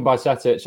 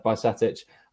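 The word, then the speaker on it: Bajčetić: But Kiefer, Stefan Bajcetic, I'll help you out with the pronunciation there, Dolly. I think that's how it's said, Bajcetic. I'm going to go with that anyway.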